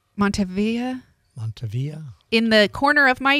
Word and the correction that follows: Mandevilla: Mandevilla. Maybe a mandevilla, you think? – Oh, maybe. Montavia. No. I thought I was doing really good and pronouncing something beautifully.